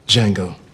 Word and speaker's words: Django: Django. – Can you spell it? – D J A N G O. The D is silent. – Django, have you always been alone? Django, Django, have you never loved again? Love will live on, oh, life must go on, oh, for you cannot spend your life regretting. Django, you must face another day.